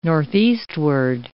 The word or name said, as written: northeastward